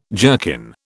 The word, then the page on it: jerkin: British English